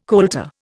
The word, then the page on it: coulter: Indian